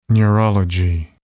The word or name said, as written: neurology